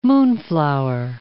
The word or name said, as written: moonflower